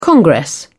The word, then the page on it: congress: British English